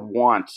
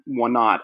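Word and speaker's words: Wanat: It's the date of the Battle of Want, or Wannat, as American troops kind of learned to mispronounce it based on the maps that they had.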